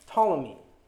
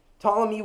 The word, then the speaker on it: Ptolemy: And the general in the south, called Ptolemy, which for those of you taking notes is spelled with a silent P at the beginning, so keep that in mind. Ptolemy would reign over Egypt.